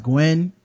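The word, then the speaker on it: Nguyen: His name is Don, I think it's Don, it's pronounced Gwen, but it's N-G-U-Y-E-N.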